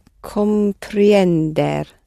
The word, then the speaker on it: comprender: Comprender. – Stress on the last syllable.